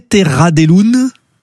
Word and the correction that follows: Terra des Lunes: Terra des Lunes. – Des Lunes, oui, voilà, oui, oui. – C'est un occitan.